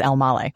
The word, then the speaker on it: Elmaleh: How you say it is Elmale, which everyone gets wrong.